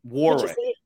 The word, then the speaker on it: Warwick: So shouldn't it be Warwick? I know where you live. – If we don't pronounce the W.